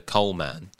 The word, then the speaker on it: Coleman: Okay, Joel Coleman. Come on, yeah. That's quite easy. He's a coal man.